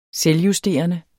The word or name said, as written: selvjusterende